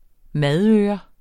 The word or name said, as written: madøre